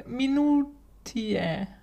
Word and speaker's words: Minutiae: Äh, ich würde es aussprechen Minutiae. Also, dass man das AE am Ende wie Ä spricht.